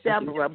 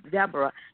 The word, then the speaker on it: Deborah: But it is Deborah, but in the Bible, It's pronounced like it is in the Bible. So it is. – Okay. – If you call me Deborah, it's okay, because my father, he named me.